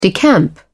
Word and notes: decamp: British English

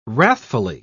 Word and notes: wrathfully: British English

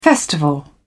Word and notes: festival: British English

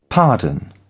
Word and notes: pardon: British English

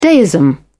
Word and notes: deism: British English

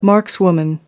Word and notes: markswoman: American English

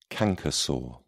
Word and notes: canker sore: British English

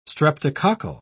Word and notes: streptococcal: British English

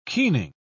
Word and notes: keening: British English

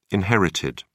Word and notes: inherited: British English

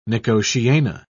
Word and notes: nicotiana: British English

